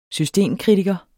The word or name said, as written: systemkritiker